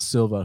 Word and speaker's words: Silva: She's 8-4, taking on Karine, it's actually pronounced, Silva, who is 14-4.